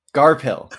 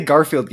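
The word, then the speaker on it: Garphill: As long as we don't say Garfield Games, which is how I misheard the pronunciation many times before I realized and saw it in text. And was like, oh, this is Garpill Games.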